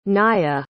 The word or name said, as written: naiya